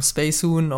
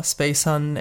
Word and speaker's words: Spacehun: You can find me under the name Spacehun or Spacehun, as the English like to pronounce it.